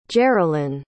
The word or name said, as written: jeralyn